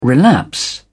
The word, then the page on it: relapse: British English